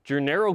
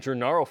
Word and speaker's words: Gennaro: We begin today's show with the conversation that happened yesterday after practice with Gennaro Guilford, which, by the way, I've been saying Gennaro forever. We found out it's Gennaro, so join me in pronouncing that correctly.